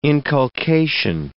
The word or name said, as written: inculcation